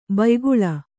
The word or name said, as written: ಭಯಾಕುಳೆ